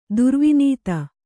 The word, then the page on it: ಧ್ರುವನಿಧಿ: dhruva nidhi